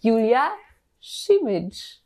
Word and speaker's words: Julia Simic: Herzlich willkommen im Podcast-Team und auch im Team Julia Simic.